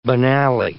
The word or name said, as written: banally